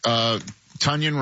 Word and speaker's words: Tonyan: You know, they got Robert, Tonyan, rhymes with Funyan, to go along with the wagon, Darnell Washington, and of course, Freiermuth.